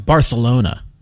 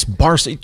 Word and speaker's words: Barcelona: Have you ever been around the people who've been to Spain and go, no, it's pronounced Barcelona? No, it's Barcelona. You're here. It's Barcelona.